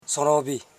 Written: so.ron.vi

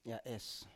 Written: ya7es

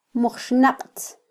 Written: mujxnaqtz

